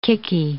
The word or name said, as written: kicky